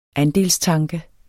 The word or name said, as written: andelstanke